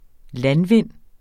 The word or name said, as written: landvind